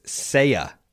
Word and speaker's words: Seiya: Red Sox and Yankees, most aggressive suitors for, it's pronounced Seiya.